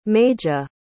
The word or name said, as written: maija